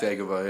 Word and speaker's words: Dagobah: Dagobah? How do you say that? – Dagobah, yeah.